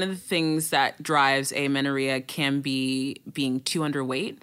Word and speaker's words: amenorrhea: Someone wants to know what you have to say about amenorrhea. How do we pronounce this? – Yeah, that is correct. Amenorrhea.